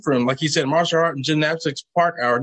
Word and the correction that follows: parkour: Did I say that right? Park hour? Park hour? Parkour? Parkour.